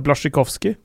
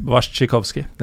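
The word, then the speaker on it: Błaszczykowski: Bla, jeg, jeg, uttaler det sånn som tyskerne gjør det. Blasjikovskij. – Og du, Morten, uttaler det Wasjikovskij. Wasjikovskij er jo sånn du uttaler det.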